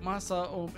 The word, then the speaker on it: Masaomi: Masaomi. It's four syllables.